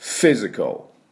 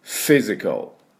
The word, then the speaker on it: physical: で、 F の音ですから、 F、エアークサブエと私は言っておりますが、下の唇を前の歯に軽く当てて、ね、ここに草があると、ふーって音がするんですけども、で、 Physical ということになります。よろしいでしょうか。では発音、私の後についていきましょう。 Physical、 Physical、はい、いいですね。